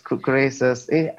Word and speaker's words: Croesus: I'm sure I'm pronouncing it correctly, but Croesus.